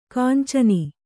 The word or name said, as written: ಕಾಂಚನಿ